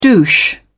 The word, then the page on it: douche: British English